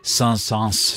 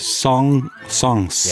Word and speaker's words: Saint-Saëns: Han hedder Camille på fransk, Camille, og så hedder han Saint-Saëns. – Saint-Saëns. Ja, altså, hvorfor skal man udtale S'et der til sidst? – Ja, fordi det ikke skal være nemt.